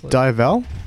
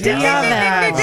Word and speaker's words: Diavel: Your word is spelled D-I-A-V-E-L. – Oh, I know. – Wait, Divel? – That is incorrect.